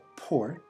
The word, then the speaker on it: Port: My name is Bob Port, P-O-R-T. Yeah. Mm-hmm. Bob, B-O-B.